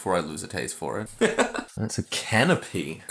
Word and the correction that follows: conpoy: Conpoy. – I'm saying canopy. I'm saying it wrong, but yeah.